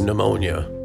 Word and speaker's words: pneumonia: Pneumonia, silent P again.